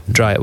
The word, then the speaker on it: Dreijer: Dreijer, Swedish. Dreyer? Dreyer? – Probably closer to Dreyer. Yeah. – Right, Dreyer. We'll go with Dreyer.